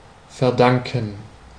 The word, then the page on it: devoir: [dø.vwaʁ]; [də.vwɛːʁ]; /də.vwaʁ/